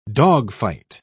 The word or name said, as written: dogfight